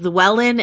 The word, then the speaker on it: Llewellyn: My name is Rena Robinson, and my middle name is Llewellyn, and it's spelled L-L-E-W-E-L-L-Y-N.